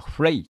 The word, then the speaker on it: from: それから 2 番の coming from the town、 coming from the town のことなんですが、 from をスコットランド方言では fray と発音し、 town が toon になります。